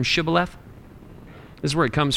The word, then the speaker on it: Shibboleth: And they would say, "All right, say the word Shibboleth." And he would say, "Sibboleth," because they couldn't pronounce it.